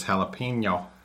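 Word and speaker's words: jalapeño: Now she says it right. – Yeah, she says jalapeno.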